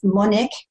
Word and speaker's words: Monique: It's Monique, like the French version, MoniqueKC, but it's spelled CaiSSIe because I have two I's, two C's, so I have two I's.